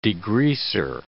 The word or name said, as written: degreaser